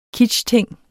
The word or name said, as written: kitschting